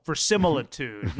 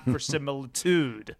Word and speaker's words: verisimilitude: The accent is on the first syllable, verisimilitude, not verisimilitude.